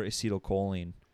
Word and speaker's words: acetylcholine: And then the other one that I mentioned before, acetylcholine is how it's pronounced.